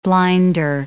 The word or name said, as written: blinder